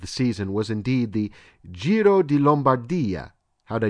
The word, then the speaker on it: Giro di Lombardia: Again, I love your show, and it's Giro di Lombardia.